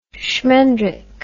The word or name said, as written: shmendrick